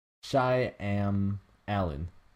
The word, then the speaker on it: Shyamalan: S-H-Y-A-M-A-L-A-N. – Yeah, there's no way. – So why? – No. It's Shy Am Alan. Oh shit. Okay, that's a good way to remember. – Shy Milan. – Shy Am Alan.